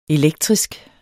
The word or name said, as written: elektrisk